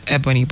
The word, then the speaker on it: Ebony: I am Ebony, by the way, okay? Ebony is spelled E-B-O-N-Y.